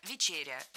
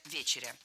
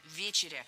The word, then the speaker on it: Вечеря: Версия ведущего – «Вечеря», версия гостя – «Вечеря». Какая из них правильная? Барабанная дробь. Гость прав. Действительно, «Вечеря». Ударение в этом слове ставим на первый слог. И в качестве шпаргалки держим в голове слово «вечер». «Вечер», «Вечеря». Легко запомнить.